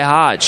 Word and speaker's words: Hoge: It's spelled H-O-G-E, like mine, Hogue. And he goes by Hodge.